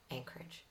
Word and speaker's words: Anchorage: And yes, it is pronounced Anchorage.